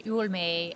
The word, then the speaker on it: Hualme: So my name is Karen Hualme, and I only say that because if you're on my Twitter, my Twitter is you old maid because that's actually how you pronounce my name. You just take off the D's.